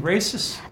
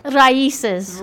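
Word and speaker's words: Raíces: Am I still mispronouncing races? – Raices.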